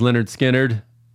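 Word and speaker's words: Lynyrd Skynyrd: Leonard Skinner, pronounced Leonard Skinner, 381.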